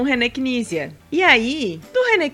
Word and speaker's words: Knizia: eu vou te mostrar como é que pronuncia é Nízia. – Nízia. – Nízia, não se fala o K, é Nízia.